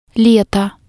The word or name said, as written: leto